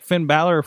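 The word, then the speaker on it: Finn Bálor: They brought out, like, Finn Balor from.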